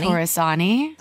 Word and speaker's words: Torresani: I don't want to like butcher your name, but I'm going to say Alessandra Torresani. – Torresani. – Close. Perfect.